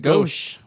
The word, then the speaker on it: Gose: G-O-S-E? – Yeah. – Mm-hmm. – Gose? – Gosh. Apparently that's what you pronounce it. Gosh.